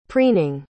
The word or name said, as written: preening